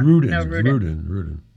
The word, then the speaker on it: Rudin: I said Rudin. Sorry. – I'm sorry. I thought you said Rumin. – I didn't pronounce it, but I didn't enunciate it enough. – Rudin.